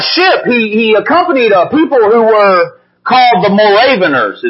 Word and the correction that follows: Moravians: Moravians, that's how you say it.